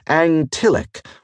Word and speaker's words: Ngtyllik: It was spelled N-G-T-Y-L-L-I-K and pronounced in a way not possible for most people with only one tongue, but Angtillic was how an Alendin would say it.